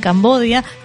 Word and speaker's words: Cambodia: En realidad, si vos lo encontrás en los mapas y hablas con la gente del lugar, todo el tiempo dicen Cambodia.